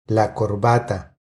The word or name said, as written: La corbata